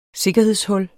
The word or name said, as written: sikkerhedshul